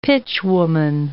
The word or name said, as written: pitchwoman